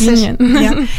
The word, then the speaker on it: Onegin: Yeah, it's so interesting because you also sang in 2024, you sang Tatiana in Eugene Onegin, I think you pronounce Onegin.